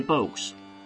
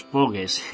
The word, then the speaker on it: Pogues: Y después tendremos a los clásicos entre los clásicos de la historia del punk rock celta, The Pogues, pronunciado de esta manera, pero que todos aquí en España conocemos popularmente como los Pogues.